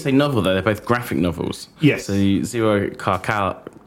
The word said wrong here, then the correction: Zerocalcare: Zero Calcare, I'll say his name right, um, writes, has written a series of novels, and both of these are based on one of his.